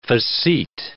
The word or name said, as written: facete